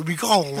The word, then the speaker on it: Miguel: Ellos tuercen la parte trasera de la lengua. Miguel.